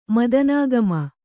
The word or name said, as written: ಮದನಾಗಮ